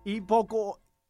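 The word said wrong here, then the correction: hipocondríaco: Naco estás, güey. No. Hipocondriaco. – Hipocondraco. – Draco. Casi dice Drácula el compa.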